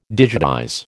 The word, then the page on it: digitize: American English